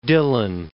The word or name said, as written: Dillon